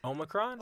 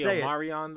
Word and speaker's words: Omicron: First of all, how do you pronounce the new COVID variant? The one that's floating around as of recording this. – Omicron? – Okay. Wow. That's correct.